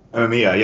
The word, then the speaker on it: Amemiya: Amemiya, yes, thank you.